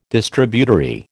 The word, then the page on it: Distributary: American English